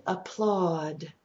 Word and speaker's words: applaud: And applaud, awed, like awed, applaud, not owed, but awed, applaud.